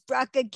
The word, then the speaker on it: Bracha: So my name is Bracha Goetz.